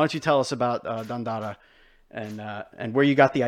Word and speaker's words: Dandara: Am I saying that correctly? Dandara, or Dandara? – I always say Dandara. – Dandara.